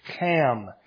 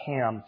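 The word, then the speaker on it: Ham (חם): you can pronounce his name correctly in Hebrew. It's Ham, not Ham.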